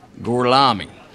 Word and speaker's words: Gorlami: Corlomi? Lo pronuncio correttamente? Uh, sì, uh, corretto. Gorlomi? Per cortesia, me lo ripeti ancora. Gorlami. Mi scusi con me? Gorlami. Ancora una volta.